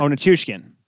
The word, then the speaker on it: Nichushkin: Oh, Nichushkin.